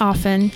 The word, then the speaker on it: often: And is it often or is it often? – It's often. I was taught the T is silent.